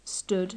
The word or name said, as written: stood